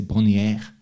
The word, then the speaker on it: Bonnier: Because in the decision that we will talk in our Swedish Bonanza episode, I don't know if it should be pronounced in a French way. I want to say Bonnier. – French, right.